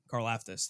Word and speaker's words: Karlaftis: The rookie first round pick this year, George Karlaftis, I believe is how it's pronounced. Karlaftis, thank you.